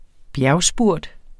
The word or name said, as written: bjergspurt